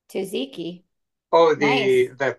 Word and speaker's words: Tzatziki: Tzatziki. Tzatziki. – Tzatziki. Tzatziki. – Okay, so you do. So it's you do pronounce the T.